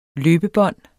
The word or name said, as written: løbebånd